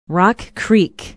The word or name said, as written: Rock Creek